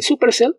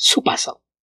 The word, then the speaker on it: Supacell: Y es también donde viene el nombre de la serie, que, como mencioné, es la exageración de la pronunciación. En lugar de supercell, es supercell.